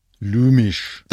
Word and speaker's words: Lumich: Ein Lumich, Lumich, Lumisch, Lumich. – Lumisch. – Dann ist es sächsisch.